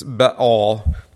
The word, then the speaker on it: Baal: It's Baal. It's two syllables, but yeah.